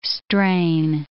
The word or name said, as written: strain